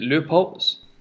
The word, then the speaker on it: Leupolz: Should we put I never really know how to say her name, but is it Leupolz? – Yeah.